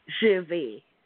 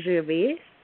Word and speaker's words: Gervais: How do you say your name? Gervois? – Gervais. – Gervais. Oh my! I'm so terrible. – Like, Ricky Gervais. You know that comedian, Ricky Gervais. – Got it. – It's Gervais. – The same pronunciation.